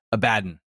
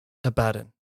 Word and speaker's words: Abaddon: And it is the way it's spelled, it should be Abaddon. – Abaddon.